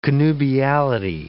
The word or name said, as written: connubiality